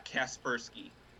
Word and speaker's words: Kaspersky: Yeah, Kaspersky.